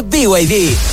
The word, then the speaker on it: BYD: ¿No es BYD? – Nada que ver, se escribe BYD, pero se dice BYD, la marca número uno de electromovilidad en Chile y el mundo.